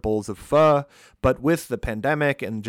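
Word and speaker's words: phở: So pho, P-H-O, pronounced pho, is a soup that comes from Vietnam, but has kind of made its way throughout Southeastern Asia.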